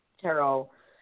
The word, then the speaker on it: tarot: Tarot has a silent T because it's also French.